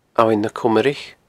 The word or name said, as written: Abhainn na Comraich